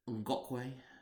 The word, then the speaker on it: Ngakoue: Myles Jack, Jalen Ramsey, Calais Campbell, Ngakoue, Cedric yep. Ngakoue. – Is that how you pronounce it? – Yeah. Good.